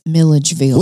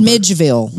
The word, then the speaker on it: Milledgeville: I said Midgeville. – Midgeville. – This is what I'm now calling syllable gate. I left all out right. an entire syllable.